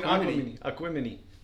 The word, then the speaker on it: Aquemini: I actually listened to the album this time, and they started singing, and it was like, they're saying Aquemini. And I was like, "Oh, that's how you pronounce it."